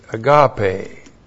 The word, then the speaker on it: agape: A-G-A-P, long E, agape.